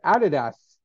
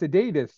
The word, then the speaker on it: Adidas: They knew Australia was pronouncing it wrong, and they're like, "Adidas, Adidas."